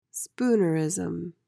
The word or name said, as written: spoonerism